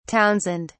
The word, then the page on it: townsend: British English